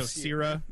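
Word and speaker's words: Scirra: It's S-C-I-R-R-A. Right. So Sierra. I would say Sierra. – And basically, it's you can get it for Sierra.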